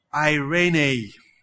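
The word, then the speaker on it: Irene (εἰρήνη): In Greek, it is, let me try and pronounce it well, Irene. When you look at the spelling, E-I-R-E-N-E, you didn't imagine it's Irene.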